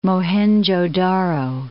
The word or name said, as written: Mohenjo Daro